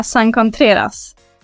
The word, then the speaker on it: San Contreras: Med mig idag i studion har jag ingen mindre än San Contreras. Så bra uttal.